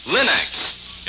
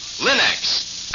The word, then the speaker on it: Linx: Remember to ask for them by name, Linex. It's spelled L-I-N-X. Linex.